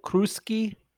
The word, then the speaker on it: Cruschi: So the ingredient today is Cruschi peppers. Am I saying that right? Cruschi? – That is correct.